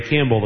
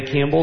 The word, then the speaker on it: Campbell: This is Ray Campbell, the President of the Illinois Council of the Blind. I Ray Campbell, C-A-M-P-B-E-L-L, will serve as delegate.